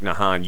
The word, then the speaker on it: Nahan: Is that how you pronounce it? – Yep. – N-A-H-A-N? – That's correct, yeah.